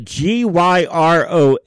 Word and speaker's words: gyros: And as we all know, because I'm sure we're all we are all advanced in our terminology, but G Y R O S is pronounced gyro, not gyro.